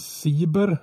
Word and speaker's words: Seeber: heter han Siber, uttalas det så? S-E-E-B-E-R.